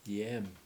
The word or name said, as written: yEEm